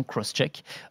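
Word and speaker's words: XCheck: Alors ça s'écrit X-check, mais ça se prononce visiblement Crosscheck, pour vérification croisée.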